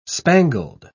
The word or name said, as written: spangled